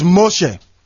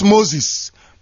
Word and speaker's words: Moshe: Moses is Moshe. Not Moses, Moshe, M-O-S-H-E. That is the correct Hebrew pronunciation. The man you call Moses, his name is Moshe, M-O-S-H-E.